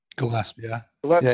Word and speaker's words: Gillespie: Gillespie.